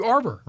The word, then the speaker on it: Arbour: Matthew Arbour. – Rhymes with harbor.